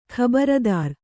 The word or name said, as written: ಖಬರದಾರಿ